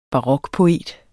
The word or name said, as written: barokpoet